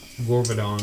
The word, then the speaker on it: Gorbadon: Do you pronounce it Garbadon? – Gorbadon. – Gorbadon. – Like Gorbachev? – Ooh, yeah.